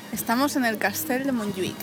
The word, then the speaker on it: Montjuïc: Montjuïc.